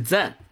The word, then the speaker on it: Zen: 就是在欧美国家的这个禅，它的英语发音是 Zen，对，就是 Zen，但是它这个发音呢，其实是来自于日语的音译。